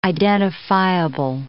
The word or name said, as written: identifiable